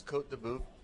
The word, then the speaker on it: Côte de Bœuf: Matt, this Côte de Bœuf, did I pronounce it right? – Yes. Yes, you're good.